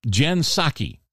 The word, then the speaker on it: Psaki: We have a new press secretary, Jen Psaki. You know, the Japanese rice wine that they serve hot sake? Yeah, well, it's spelled P-S-A-K-I, but it's pronounced like the Japanese rice wine, sake.